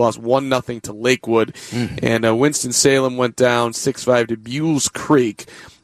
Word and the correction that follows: Buies Creek: which I'm still learning how to pronounce. Buies Creek. Thank you, Andy Mazur. Buies Creek. Did you know that? It's B-U-L-E-S, Harry, but it's pronounced Buies.